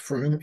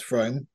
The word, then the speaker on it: Frome: So this weekend, we get to go to Frome, which is pronounced Frome. It's not pronounced Frome because I've done the research,